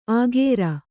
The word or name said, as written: ಅಖೇರ